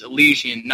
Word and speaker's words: Elysian: And also the how I believe the correct pronunciation of the Elysian fields is Elysian, not remember how you said it.